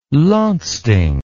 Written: landsting

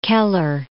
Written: Keller

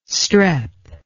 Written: strep

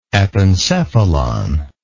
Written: epencephalic

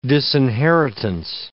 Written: disinheritance

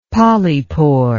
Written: polypore

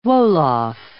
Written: Wolof

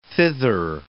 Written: thither

